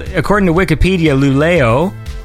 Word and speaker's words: Luleå: So this track is called Lulea, like L-U-L-E-A, which is actually a place. So there's a place in Sweden, and Wikipedia says it's pronounced Luleå. Does that sound right to you? – Luleå.